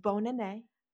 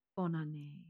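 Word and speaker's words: Bonne année: Bon année. – Bon année.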